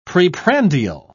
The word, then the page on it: preprandial: British English